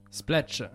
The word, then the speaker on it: Splatch: Ça se prononce comment, Splatch? – Splatch. – Splatch, oui. Comme le bruit, quoi.